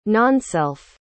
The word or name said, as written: nonself